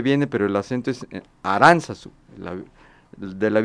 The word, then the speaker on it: Aránzazu: que hay que decirlo, no lleva el acento en la U, lo lleva en la segunda A, Aranzazú, no Aranzazú.